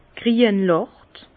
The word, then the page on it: grienlacht: Bas Rhin; Herrlisheim